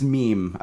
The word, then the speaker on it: meme: No, it's meme. I believe Richard Dawkins coined that term, and I think it's... Is it a title of a book? He pronounces it meme, though.